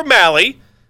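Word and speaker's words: Mahle: Tyler Malley, and I always remember how to pronounce his name because it rhymes with 10-run rally, because that's what he's usually going to give up on the mound.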